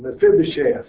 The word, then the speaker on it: Mephibosheth: Ruth is teaching tomorrow on Mephibosheth. Did I say that right? – You did.